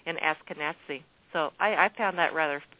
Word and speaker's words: Ashkenazi: Ashkenazi.